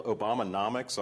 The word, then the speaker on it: Obamanomics: Let's start off with the title, Obamanomics. I'm pronouncing that right? – Yes.